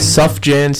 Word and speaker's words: Sufjan: Sufjan Stevens, I believe it's pronounced.